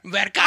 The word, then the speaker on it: Vergara: How do you say her last name? – Vergara. – Vergara. – Vergara. I got it right.